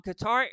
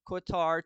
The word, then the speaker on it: Qatar: How do you say it? – Qatar. Yeah, mm-hmm. Qatar, the country. – Yep. – Yeah. – So on Qatar Airways flight from Qatar to Bali, Indonesia, a woman accessed her husband's phone and discovered that the man was cheating on her.